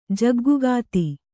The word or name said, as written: ಜಗ್ಗುಗಾತಿ